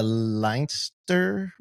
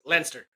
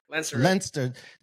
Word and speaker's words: Leinster: Prior to founding Kitman Labs, Stephen was senior injury rehabilitation and conditioning coach with the Leinster Rugby Club. Did I pronounce that right? – Close. Leinster. – Leinster.